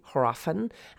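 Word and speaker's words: hræfn: but H-R-A-E-F-N. Now that A-E is that Old English runic symbol that you know, looks like an A and an E smushed together, that today would be pronounced eh, but then it would have been more of an a sound. So this would have been basically hrafen.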